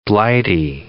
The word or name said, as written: Blighty